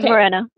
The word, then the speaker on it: Moreno: Moreno.